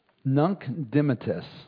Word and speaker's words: Nunc dimittis: Nunc dimittis, N-U-N-C, first word, D-I-M-I-T-T-I-S.